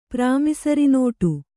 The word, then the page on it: ಪ್ರಾಮಿಸರಿನೋಟು: prāmisari nōṭu